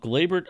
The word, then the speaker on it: Gleyber: So, it was a video, and I believe it's actually posted on our Twitter as well, of Gleyber. I hope I'm saying it right. Gleyber Torres? Is that how I'm saying it? – Yes. – Is that how you say it? – Gleyber Torres. Yep.